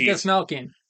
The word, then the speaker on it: Dastmalchian: David Dastmalchian. – Is that, did I mispronounce his name? – A little bit. – David Malchian. I didn't see the N.